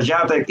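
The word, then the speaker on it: Žatec: se escribía Z-A-T-S. – Sí, sí, sí, sí, ¿se cuál es. Se, pronuncia Zatec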